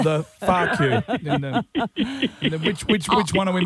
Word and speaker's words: pho: And it's the same with the Vietnamese noodle soup, which it's P-H-O, so you're like, oh, can I please have a pho? – Yeah. – And then you get laughed at, and you're told, no, it's pronounced Far. – It is far.